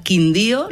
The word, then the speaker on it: Quindío: Bueno, física, astrofísica, investigadora colombiana, nacida en Calarcá, Quindío, ¿lo pronuncio bien? – Perfectamente.